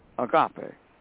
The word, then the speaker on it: agape: How do you say the Greek way again? – Agape.